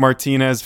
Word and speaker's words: Martinez: I have confirmed it is pronounced Blake Martinez. Found a video of Peter Schrager saying Martinez, but he was used to a productive extent in Green Bay.